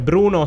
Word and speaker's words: BR1: Allora ne approfittiamo per chiarire agli ascoltatori che Bruno si pronuncia così ma si scrive BR è numero uno, quindi BR1.